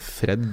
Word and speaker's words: Fred: Ikke Fredge, men Fred.